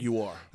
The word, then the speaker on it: Jaguar: Jaguar. – I'll explain. I'm sorry. That's the correct pronunciation.